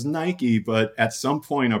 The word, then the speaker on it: Nike: no, we pronounce it Nike.